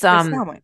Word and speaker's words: Samhain: Samhain is the Celtic harvest festival. And did I say that right? – Samhain. Yeah. It's Samhain.